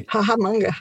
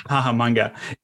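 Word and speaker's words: Hahamongna: You know, how different was the village Hahamunga if I'm pronouncing that correctly. – Hahamanga. – Hahamanga.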